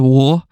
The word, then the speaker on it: Waugh: Journalist Paul Waugh, is that how you say it? Waugh, W-A-U-G-H,